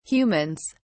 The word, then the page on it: humans: British English